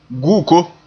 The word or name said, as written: guko